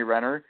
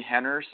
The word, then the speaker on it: Renner: I mean, I've seen most of the Bourne legacy with Jeremy Renner. And Sean, I got to say earlier you pronounced it Jeremy Henner, so I don't know if you're going Brazilian on us or something like that.